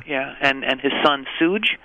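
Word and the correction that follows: Suge: Shug. – Shug. What is that? – That's his name. – No, it's not. It's Sooge. – No, it's not Sooge. It's Shug.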